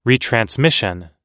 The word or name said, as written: retransmission